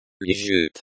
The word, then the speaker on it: лежит: In the example sentence, the stress is on the second syllable of лежит.